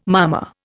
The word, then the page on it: mama: Indian